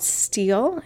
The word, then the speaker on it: stele: There are some things called stele. It's spelled S-T-E-L-E.